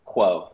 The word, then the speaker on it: Kuo: It's like quo, like status quo or quid pro quo.